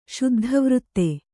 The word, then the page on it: ಶುದ್ಧವೃತ್ತ: śuddha vřtta